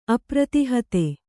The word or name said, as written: ಅಪ್ರತಿಹತೆ